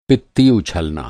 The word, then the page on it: पित्ती: Hindi